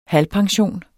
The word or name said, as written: halvpension